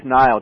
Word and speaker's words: Niall: Tell us about Niall Cox. Am I saying that correctly? – Yes, Niall, just like the soap, yeah.